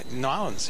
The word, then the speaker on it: New Orleans: Now, I come over here to talk to you about New Orleans because you are the embodiment of New Orleans, or should I say Norlands. – Norlands. – Did I say that right? – Norlands, yeah. I've been,